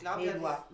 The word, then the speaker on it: Melua: Melua heißt, wird die ausgesprochen. – Melua.